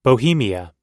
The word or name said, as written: bohemia